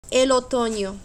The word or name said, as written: el otono